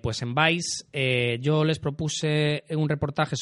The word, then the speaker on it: Vice: Sí, os comento, yo propuse a Vice, que se escribe V-I-C-E,